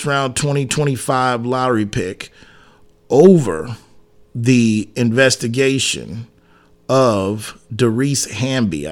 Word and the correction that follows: Dearica Hamby: I believe is how you say it. I hope not probably butchered the name. – Um, Derica? – Hamby. Okay, I'm sorry. It was Dearica Hamby. Dearica Hamby investigation.